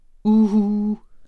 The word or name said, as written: uhu